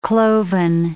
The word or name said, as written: cloven